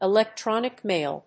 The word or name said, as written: electronic mail